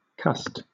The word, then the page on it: cussed: British English